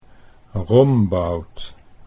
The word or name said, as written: Rombout